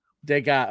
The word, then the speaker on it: Degas: Degas.